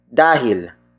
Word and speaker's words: dahil: The next word is dahil. Dahil. Dahil. Dahil is spelled as D-A-H-I-L.